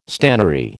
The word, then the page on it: stannary: American English